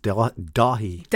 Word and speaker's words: Daithí: Jennifer's the closest with Dahi. – Dahi? – Dahi. – Dahi. – Oh, Dahi. – Without the T. – Okay. – Yeah. – So the T is silent.